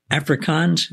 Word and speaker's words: Afrikaans: So you grew up speaking Afrikaans.